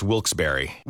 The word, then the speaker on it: Wilkes-Barre: Now, I had heard that it was supposed to be pronounced Wilkes-Barre.